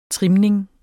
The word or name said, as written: trimning